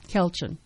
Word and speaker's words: Kelchin: Kelchin, just like it sounds.